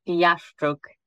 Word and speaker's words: Jaszczuk: It's Jaszczuk.